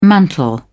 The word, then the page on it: mantle: British English